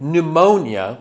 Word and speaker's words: pneumonia: Like pneumonia, we don't pronounce the P in English.